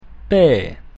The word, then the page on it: P: Latin